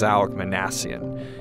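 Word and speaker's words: Minassian: Noor was based in New York City, and a lot of American reporters mispronounced his name. But I corrected her and said, "His name is Alec Manassian."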